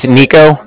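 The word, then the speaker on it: Niko: My name is Nico, that's N-I-K-O.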